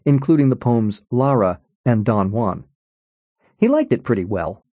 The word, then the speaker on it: Don Juan: "It's pronounced Don Juan," he said. "That's true. Much of the meter in Don Juan only works if you read Juan as bisyllabic."